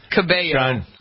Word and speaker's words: Cabello: Apparently, let's see here, Camila Cabello, am I pronouncing it? Cabello, I guess, right? – Cabello.